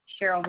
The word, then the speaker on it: Cheryl: Cheryl Hill. – Can you spell it for me? – C-H-E-R-Y-L.